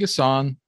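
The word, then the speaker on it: N'Guessan: David Gasson, the N is silent.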